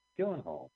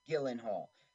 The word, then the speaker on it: Gyllenhaal: Gill, Gyllenhaal. Gyllenhaal. Maggie Gyllenhaal was paid bucks.